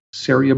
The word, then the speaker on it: Ceria: Yeah, C-E-R-I-A. Yeah. And pronounced, it rhymes with area, Saria Brewing Company.